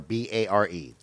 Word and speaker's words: Bexar: No, Bexar or Bare. That's Bexar. – But they spell it Bexar.